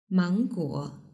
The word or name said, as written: mangguo